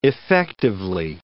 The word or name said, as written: effectively